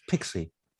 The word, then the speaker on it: PX: And that's PX pronounced Pixie.